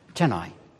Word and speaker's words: Chennai: It's in a place called Chennai. I think that's the way you pronounce it. Chennai. Thank you. Yes, that's what I was going to say.